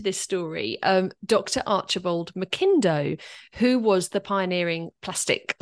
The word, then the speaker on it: McIndoe: He's they pronounced it McIndoe. – McIndoe. – Ah, McIndoe. Okay. okay. doesn't have the A in it, so it makes it difficult to know. But he ah, pronounced it ah, McIndoe, okay. and he was always they were always correcting people. And if you said it to a guinea pig, they would correct you.